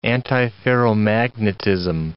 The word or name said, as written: antiferromagnetism